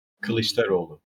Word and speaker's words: Kılıçdaroğlu: Kilicdaroglu.